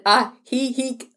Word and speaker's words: Ajijic: We stayed in Ajijic as a taxi cab driver pronounced it Ajijic, like the laughing village.